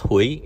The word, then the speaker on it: Huie: First name is Gordon, last name is pronounced Hughie, H-U-I-E.